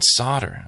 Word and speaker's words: solder: I pointed at the wave soldering machine and they said, oh, you mean solder? And I'm like, yes, I mean solder. Sorry. – Yeah, that's a weird one. – I know. Anyway, I don't get that. The L disappears because it's spelt the same way.